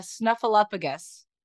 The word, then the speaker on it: Snuffleupagus: Earlier in the day, Heather said that Emily looked like a snuffleupagus in a good way.